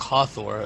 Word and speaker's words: Hathor: The Hathor aspect, Hathor, and I'll go by your pronunciation.